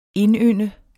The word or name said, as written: indynde